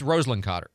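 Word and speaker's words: Rosalynn: It's Roslyn Carter. Not Roslyn. Just somebody was asking.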